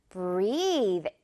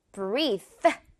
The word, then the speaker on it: breathe: breathe 이렇게 발음이 돼요. 마지막 부분, 이 th 부분은 th 소리이기 때문에 절대 breathe 이렇게 쌍디귿 같은 TH를 끝내면 안 된다는 거.